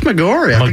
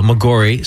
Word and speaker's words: Maggiore: and then at its southern end is Lake Maggiore. Did I pronounce that right? – I think it's Maggiore. – Okay, there you go. Maggiore.